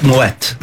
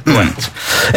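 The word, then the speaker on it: Moët: If anyone's wondering about the pronunciation, it's because there's a two little dots over the E, which means it's you pronounce both syllables of the word, so it's Moet. Moet.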